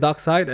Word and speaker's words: Darkseid: It's pronounced Darkseid, and the demon is a parademon, they call it.